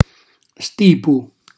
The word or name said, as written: stipu